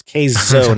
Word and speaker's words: Keizo: It's spelled K-E-I-Z-O. But people are going to mispronounce it and say Keizo because, K-E-I, Keith. But it's Keizo, pronounced Keizo, K-A-Y-Z-O is the pronunciation.